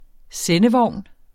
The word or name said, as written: sendevogn